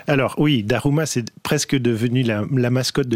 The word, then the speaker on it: Daruma: Ça s'appelle un Daruma, je prononce bien.